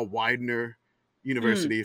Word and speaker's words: Widener University: There was something called the Boardwalk Bowl before that, and that's just basically Pennsylvania Military College, now Widener University, if I said that wrong.